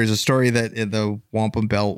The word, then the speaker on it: wampum: But there is like, we, you know, all jokes aside, with the Wanup belt, is it the Wanup belt? How do I say it again? – The wampum.